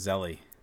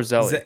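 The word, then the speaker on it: Zelle: What is Zelly? – Do you not know what Zelly is? Or is it pronounced Zell? Is it Zell or Zelly? – It's Zell.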